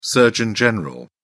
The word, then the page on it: surgeon general: British English